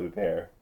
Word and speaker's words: Huppert: And that handbag owner just happens to be Greta, played by Isabelle Huppert. – Isabelle Huppert. I'm glad you said her name first because I wouldn't have thought to have pronounced it the French way for some reason.